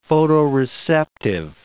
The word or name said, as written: photoreceptive